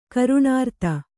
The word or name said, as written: ಕರ್ಣೀರಥ